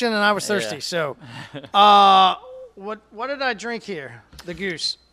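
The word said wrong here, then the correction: Gose: So it's an old German style of beer, pronounced Goza, by the way. – Oh. – Everyone calls it that, by the way.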